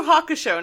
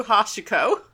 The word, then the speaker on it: Hakusho: It's Boo Boo Hakusho, not Boo Boo Hashiko. – Is that not what I've been saying? – Yeah, you've been saying Boo Boo Hashiko. – Boo Boo Hakusho.